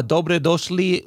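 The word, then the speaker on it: dobro došli: Yana, dobre došli u podcast. Did I say that right? – Oh my God. You nailed it.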